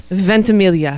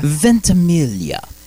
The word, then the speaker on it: Ventimiglia: Ventimiglia. – Ventimiglia.